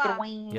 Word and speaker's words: Drouin: Drouin.